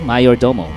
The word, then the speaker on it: Majordomo: It's Latin, you know, Majordomo. J is pronounced Y in Latin.